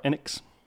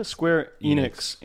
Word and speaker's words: Square Enix: At last week's Square Enix event, did I pronounce that right? Enix. – Enix. Sure. Square Enix. – Sure. Enix. Yeah, Square Enix. Enix.